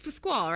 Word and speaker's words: Pasquale: singing again, I go back to if you're casting Steven Pasquale, and again, it's it's Pasquale, right? Is how it's pronounced. I've I've done it wrong before.